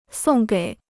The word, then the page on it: 送给: sòng gěi